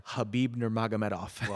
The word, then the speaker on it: Khabib Nurmagomedov: Did you say that again a little faster? – Khabib Nurmagomedov. – Well done.